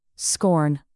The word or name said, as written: scorn